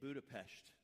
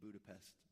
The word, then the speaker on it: Budapest: The four of us went, and we started in Hungary, in Budapest. That's how you pronounce it. It seems like it should be Budapest, but it's not.